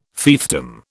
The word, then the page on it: fiefdom: British English